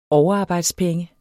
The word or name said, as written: overarbejdspenge